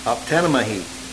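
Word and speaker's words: Optanamahi (ὀπτάνομαι): And you say it Optanamahi.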